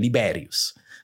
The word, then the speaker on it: Liberius: Liberius. Mm-hmm. Sorry if I, let's use the Latin pronunciation, Liberius.